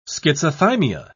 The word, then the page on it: schizothymia: British English